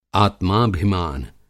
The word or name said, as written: आत्माभिमान